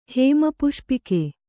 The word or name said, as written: ಹೇಮಪುಷ್ಪಕ